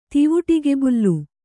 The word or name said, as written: ತಿವಿಟಿಕೆಬುಲ್ಲು